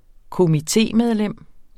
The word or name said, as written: komitemedlems